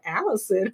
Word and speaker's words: Allyson: So I just wanted to clear that because people are gonna be like, Allyson, who is that? Trust still me, spelled the same A-L-L-Y-S-O-N.